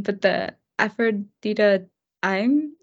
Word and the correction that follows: Aphrodita Ime: initiative. – It's Aphrodita Ime.